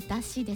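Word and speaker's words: 山車: う一つのワードはこちら、だし。はい、みこしと似ていますが、だしです。山の車と書いてだし。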